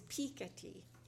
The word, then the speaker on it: Piketty: Anyway, according to the New York Times, it's Piketty.